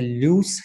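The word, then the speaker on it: Luce: And Joanne began her career as a loose scholar. Is that the correct pronunciation? – Yes, it is.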